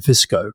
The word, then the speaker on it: Fico: This is this guy, yet another populist, Robert, it's spelled F-I-C-O, but I think you pronounce it Fisco.